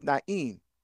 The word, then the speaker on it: Nain: Soon afterward, he went to a town called Nain. Now, again, N-A-I-N. Now, I'm pronouncing it the Hebrew way, right? Now, sometimes Nain, N-A-N, okay, that's English. So when I say Nain, that's the Hebrew, okay?